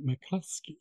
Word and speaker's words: McCluskey: McCluskey.